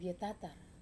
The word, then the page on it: vetatar: Wamesa